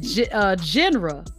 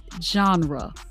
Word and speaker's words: genre: One of our guys said Jenner. I couldn't figure out what he was talking about. And he's so intelligent and educated. And I said, it's pronounced genre.